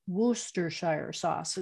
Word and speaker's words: Worcestershire: Oops, my son told me I have to say Worcestershire sauce is the right way to say it. – Okay. – He has a friend that lives in Worcestershire, England. So he told me that's the right way to say it. And they make it there.